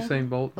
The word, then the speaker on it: Usain: Usain, is that how you say his name? – Usain Bolt, yeah.